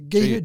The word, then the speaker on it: Gita: I think I might have mispronounced that too. It might be the Gita. – Anyway, yeah, Gita, Gita. – G-I-T-A. – Well, Rita would be R-I-T-A, and it's pronounced Rita, so Gita, Rita. – Gita, but yeah, might be, anyway, sorry, but I digress. – The G-I-T-A robot, it's got two versions.